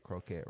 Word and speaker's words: croquette: I'd rather say salmon patty because I don't know if I'm saying croquette right. – You saying it right, it's fucking fried tuna fish, it looked like.